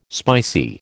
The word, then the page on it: spicy: American English